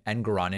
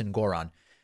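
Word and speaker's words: Engoron: Good for Judge Ngoron, who I guess I've been pronouncing his last name Ngoron incorrectly. Apparently it's Ngoron.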